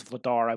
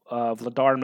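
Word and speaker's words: Vladař: Vladar, I believe is how his name is pronounced.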